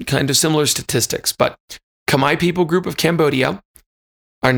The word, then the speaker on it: Khmer: Forrest chose one of them in there, and that's the Khmer people, K H M E R. It looks like it would be pronounced Khmer, but they've told me it sounded it sounds like Khmer.